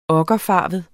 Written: okkerfarvet

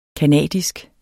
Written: canadisk

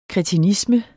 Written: kretinisme

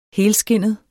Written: HELSKINDET

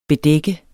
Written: bedække

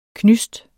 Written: knysts

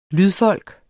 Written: lydfolk

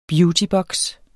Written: BEAUTYBOKSE

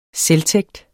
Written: selvtægt

